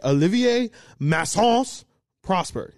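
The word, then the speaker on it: Olivier-Maxence Prosper: He said, Olivier Massence Prosper. – Massence. – Yeah, he like really hit the Massence.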